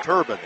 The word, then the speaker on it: Thorbahn: Get this. Turban. It's spelled T-H-O-R-B-A-H-N. – Her name's Alexis today for me.